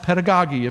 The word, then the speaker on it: pedagogy: Now, the word training here, the root word behind it, it's the same root word from which teachers get their word pedagogy, or it's sometimes pronounced pedagogy, if you've heard it that way.